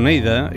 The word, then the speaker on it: Oneida: Ells es diuen Oneida i no ho pronuncio en anglès, ho llegeixo tal qual.